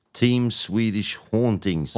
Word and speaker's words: Team Swedish Hauntings: Uttalar jag det korrekt eller vill du göra om det? – Team Swedish Hauntings. – Hauntings, ja.